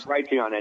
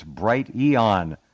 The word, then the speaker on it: Brighteon: Well, I'm not going to be on Brighteon anymore. Forget him. – Yeah, he still doesn't know how to pronounce his enterprise there. It's Brighteon. Not bright eon.